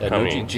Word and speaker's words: Cummins: Cummins, not Cummins. – G is silent.